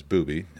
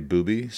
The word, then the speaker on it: Bube: The name is Bubi. It's spelled B-U-B-E. It's a German name, and a lot of German names end in E, and when they do, you pronounce the E. So it's very confusing for most people in English to say it right.